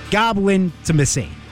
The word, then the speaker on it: Goblin-timacy: Goblin-timacy. That's how you say it. You got to say it fast. I had to say it slow because it's very weird.